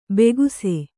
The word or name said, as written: ಭಾಗಿಸು